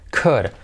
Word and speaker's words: could: Could. I'm sorry. I couldn't do it. Negativo couldn't. Oh yes, I could do it. Good. No pronunciamos la L.